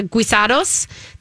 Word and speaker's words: guisado: Did I say guisados? – Guisados. Guisado. She taught me how to say it because I was saying it wrong.